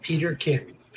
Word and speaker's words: Peterkin: It is just like it's spelled, Peterkin. Peter with Peterkin. K-I-N.